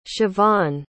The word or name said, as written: shavonne